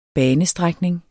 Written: bane

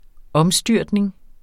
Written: omstyrtning

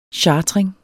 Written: chartring

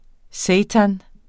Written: seitan

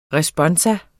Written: RESPONSUMS

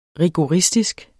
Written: rigoristisk

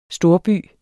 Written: storby

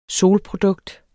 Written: solprodukt

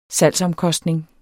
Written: salgsomkostning

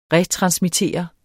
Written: retransmittere

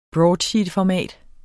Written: broadsheetformat

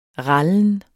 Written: rallen

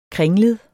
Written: kringlet

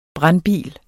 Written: brandbil

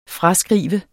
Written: fraskrive

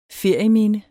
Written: ferieminde

